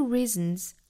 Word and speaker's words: reasons: There are many reasons. Reason 这个词注意长元音 e, 嘴角裂开紧张度。 Reason, 那它变成了复数的这个 s 要读成 z 的发音，清清浊浊嘛，因为 reason 尾部是一个鼻辅音，鼻辅音属于浊辅音嘛。